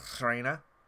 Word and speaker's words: Reina: Mac X, Selena La Reina. I can't do my R's. I'm sorry. – Roll your R's. – Reina. Okay, close.